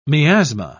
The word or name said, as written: miasma